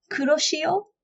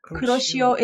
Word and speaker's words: Kuroshio: It's as you say it, K-U-R-O, as in Kuro, is the color black. And she is S-H-I-O. Shio, Kuroshio. Kuroshio is a Japanese word for the Black Sea current in Pacific Ocean.